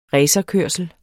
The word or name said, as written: racerkørsel